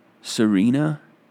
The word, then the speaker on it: Tsarina: His album Serena, it's spelled T-S-A-R-I-N-A.